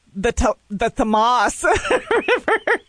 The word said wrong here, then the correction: Thames: The Thames River in England.